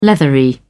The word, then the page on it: leathery: British English